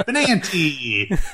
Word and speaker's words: Benanti: Like, this is, come on, Benanti. Benanti business. What is that? – Benanti.